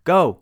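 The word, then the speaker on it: go (五): So the answer, 5-5 in Japanese means go, go. Because 5 is pronounced go in Japanese.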